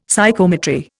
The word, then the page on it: Psychometry: Indian